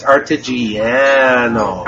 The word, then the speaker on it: Artigiano: How do you pronounce Artigiano? – I don't know. I thought it was Artigiano. That's what I thought it was. – No, it's Artigiano.